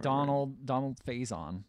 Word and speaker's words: Donald Faison: Donald Faison.